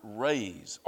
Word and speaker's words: raze: And just by the way, that word raise, R-A-Z-E, it's pronounced exactly like R-A-I-S-E, a word that means exact opposite.